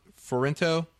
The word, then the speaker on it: Fiorentino: So in addition to having Tommy Lee Jones and Will Smith, you have Linda Fiorentino. – Spelled like it sounds.